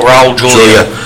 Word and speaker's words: Raul Julia: I'm probably butchering the man's name. Raul Julia. – Oh, Raul Julia.